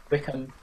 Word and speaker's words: Wycombe: I meant Wickham, not Wycombe, as apparently it is called Wickham. So recently, right, there was their star man Adebayo Akinfenwa.